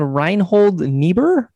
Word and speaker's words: Reinhold Niebuhr: It's attributed to a guy named Reinhold Niebuhr.